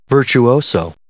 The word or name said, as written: virtuoso